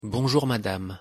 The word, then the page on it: bonjour madame: French